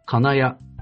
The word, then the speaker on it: 金谷: そして3期、金谷は名古屋のアクセントでしたが、こちらは渋谷のアクセントでいいんです。金谷なんですね。